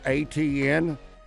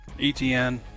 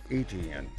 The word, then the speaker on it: Etienne: Who is the Florida running back called ATN? – Etienne, yeah, Travis. – I thought it was ETN. – ETN, yeah, I think that's how you say it. His brother's in the NFL. – Yeah, ETN.